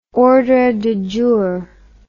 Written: ordre du jour

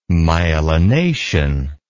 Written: myelination

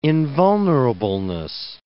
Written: invulnerableness

